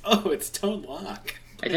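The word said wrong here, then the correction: Lōc: I think I it's pronounced Loke.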